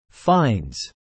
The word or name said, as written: fines